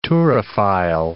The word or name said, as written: turophile